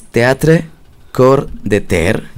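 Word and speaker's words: Theatre Coeur de Terre: Esto es Teatre Cor de Ter. Yo lo leo bien en chileno y ahora lo deletreo. Es T-H-E-A-T-R-E, Teatre Cor, que se escribe C-O-E-U-R, D como D-E, y Terre, T-E-R-R-E.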